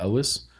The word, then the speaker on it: Elwes: Yeah, I'm going to mispronounce his last name, but Cary Elwes. Mm-hmm. Elwes? – You got it right.